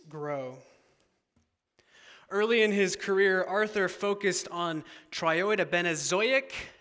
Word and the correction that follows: triiodobenzoic: acid. Did I say that right, Jason? Is he here? He left. Okay. Triiodobenzoic acid, which is a hormone that helps the flowering of soybeans.